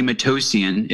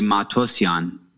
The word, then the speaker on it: Matossian: If you're there's two ways to pronounce it. If you're not Armenian, you say Matossian. If you're Armenian, you say Matossian.